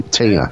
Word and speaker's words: tiener: The song is T I E N E R, which I am pronouncing Tina.